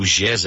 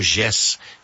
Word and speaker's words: Jess: Essa marcha vai sair da porta da nossa igreja, ali de Araucária, nossa sede central, que é na rua Pedro Gés 158, e o Gés é bom que se diga que se escreve com J, tá? J-E-S-S, para que vocês possam buscar no aplicativo aí que vocês usam para navegar, o aplicativo de navegação, né?